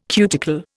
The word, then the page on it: cuticle: Indian